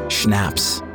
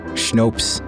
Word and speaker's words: schnapps: You pronounce it schnapps, you dumb fuck, like shut up and stop, smooshed together.